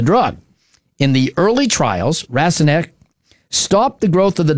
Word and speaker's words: Rasonque: This new drug is called Rasonque, and it's spelled R-A-S-O-N-Q-U-E.